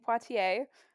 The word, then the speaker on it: Poitier: Sydney, I looked up the pronunciation because I can never say it right, Poitier, which you're like, huh, I know a Sydney Poitier who is a man.